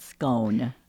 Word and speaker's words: scone: The word is spelled S-C-O-N-E. How do you pronounce that? – I have always said scone. – Yes, and that is one of the pronunciations. I have heard it pronounced scone, like rhymes with Ron.